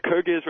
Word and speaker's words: Kyrgyz: Prior to joining CEI, by the way, was a Peace Corps volunteer in the, how do you pronounce that, Kyrgyz Republic? – Kyrgyz Republic, yes, sir.